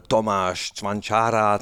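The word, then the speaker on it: Tomáš Čvančara: Äh, Tomasz Zwanchara zum Beispiel.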